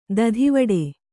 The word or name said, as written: ತೊತ್ತುವೇಟ